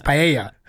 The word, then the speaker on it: paella: Well, it's, everyone knows it's two L's is a Y. It's paella.